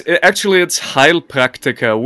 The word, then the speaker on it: Heilpraktiker: Heilpraktiker. – Well, no, it's Heilpraktiker, which literally translated means heal practitioner.